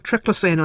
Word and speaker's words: triclosan: Or is that how you pronounce it? – I say triclosan, okay, but I don't actually know what's correct. – No, yours will be correct.